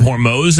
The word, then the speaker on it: Hormuz: We've been saying it wrong, by the way. It's the Strait of Hormuz, apparently.